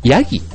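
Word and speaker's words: 山羊: あとね、えっ、ー、と、そう、羊というと、えっ、ー、と、ヤギ。ね、山の羊と書いてヤギと読みますよね。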